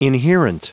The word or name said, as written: inherent